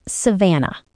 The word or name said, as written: savanna